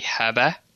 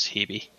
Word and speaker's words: Hebe: And the official Greek pronunciation would be Hebe. – Oh, wow, so okay. – In English, it's generally pronounced as Hebe.